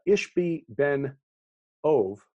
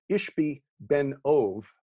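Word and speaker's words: Ishbi-benob: Ishbi ben Ov, because the B in Hebrew is actually pronounced like a V in English. So Ishbi ben Ov would be the correct way of reading his name.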